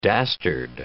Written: dastard

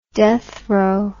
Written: deathrow

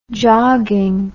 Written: jogging

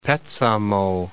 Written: Petsamo

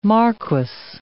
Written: marquis

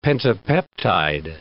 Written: pentapeptide

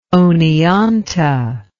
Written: Oneonta

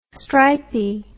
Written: stripey

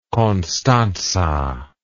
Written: constanta

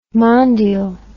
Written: mondial

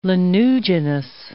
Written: lanuginous

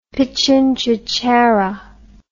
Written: pitjantjatjara

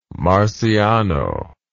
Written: Marciano

